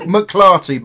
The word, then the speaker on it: McClarty: I'll do a joker, like a wee McCarty. – Whoa! It's actually look McClarty, but the phonetically correct.